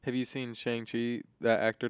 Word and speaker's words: Shang-Chi: Shang-Chi, yeah.